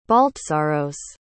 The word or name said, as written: baltsaros